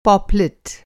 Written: पॉपलेट

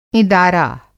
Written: इँदारा

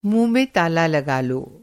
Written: ताला

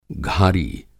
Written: घारी

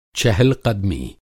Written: चहलक़दमी

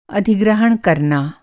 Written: अधिग्रहण

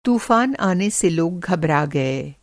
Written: घबराना

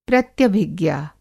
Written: प्रत्यभिज्ञा

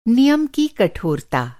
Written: कठोरता

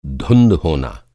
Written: धुँध

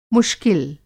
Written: मुश्किल

